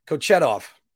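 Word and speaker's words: Kochetkov: because I wanted to make sure I pronounce it right. Kochetov, Peter Kochetov. So I did not butcher a name or call him Justin.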